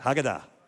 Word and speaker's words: Haggadah: Can you say that? Haggadah. You're going to learn some Hebrew today. Accent's always on the last syllable. Haggadah.